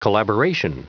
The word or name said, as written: collaboration